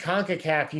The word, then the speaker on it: CONCACAF: It's CONCACAF, right? – You got it. Nailed it.